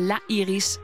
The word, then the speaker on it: La Iris: En even, het is even voor de mensen thuis. Het is La en dan Iris in het Nederlands. Maar La Iris, zo spreek je het uit. Als in de Iris. De enige echte.